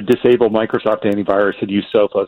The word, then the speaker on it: Sophos: S-O-P-H-O-S. Correct? – Yep. – And that's pronounced Sophos. – Yeah. – S-O-P-H-O-S.